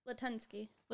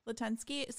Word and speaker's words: Latunski: So, okay, so this is after Latunski? Is that how you say his name? – Latunski. – Latunski.